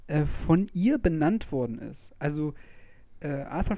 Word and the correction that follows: Aretha: Franklin, äh, nee, jetzt habe ich schon wieder falsch ausgesprochen. – Aretha? Aretha.